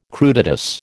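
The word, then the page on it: crudités: American English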